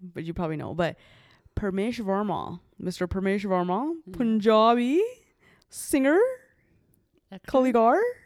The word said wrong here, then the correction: Kalakar: Is that how you say it? Kaligar? – Kalakar.